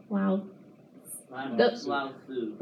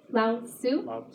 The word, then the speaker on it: Lao Tzu: Lao Tzu. – What is it? – Lao Tzu. – See, T and Z really shouldn't be there. Yeah, it's like Leo Tzu. But you said it right.